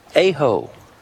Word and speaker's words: Aho: Brant Aho. Well, it depends on how you pronounce that, Ashton. Aho, Aho, A-H-O.